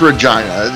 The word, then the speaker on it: Regina: It's Regina. The easy tip is rhymes with vagina.